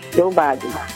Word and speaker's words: Joe Valle: Joe, J-O-E. – Sí, Joe Valle.